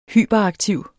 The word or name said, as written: hyperaktiv